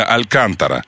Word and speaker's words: Алькантара: Скажите людям, вот мне Кения пишет, Алькантара, да, ударение Алькантара. – Алькантара, конечно, безусловно.